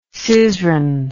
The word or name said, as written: syzran